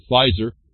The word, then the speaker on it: Pfizer: I think we reported on all this, the memory hole type stuff, that Pfizer, the P is silent, like pharmaceuticals, that they were found with Upjohn as a unit, they're both together, resolve a criminal and civil liability case.